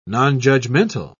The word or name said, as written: non-judgmental